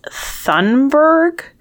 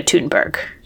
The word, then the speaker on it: Thunberg: Michal rang in and said, who is Thunberg? And Katie Couric said, we'll take it, but it's Greta Thunberg.